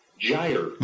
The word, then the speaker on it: Gyre: It was Gyre. – There you go. Up Gyre. – That's the correct pronunciation. – Up Gyre, G-Y-R-E .org.